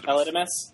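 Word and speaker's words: Aledmys: Now that Aldames Diaz, or however you pronounce his Aledmus. – Name. Aledmus.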